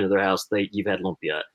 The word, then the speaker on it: lumpia: Lumpia is how you say it. L-U-M-P-I-A. Lumpia.